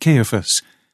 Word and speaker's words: Caiaphas: The same with Caiaphas or Caiaphas. The Scriptures Committee has asked that I pronounce it Caiaphas.